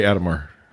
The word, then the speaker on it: Adhemar: I say Adhemar. – Adhemar? – That's how I've been saying it. – That's how you've been saying it. Because it's A-D-H.